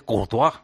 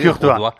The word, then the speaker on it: Courtois: Por ejemplo, tienes razón que es Courtois. Eso es así. – Courtois.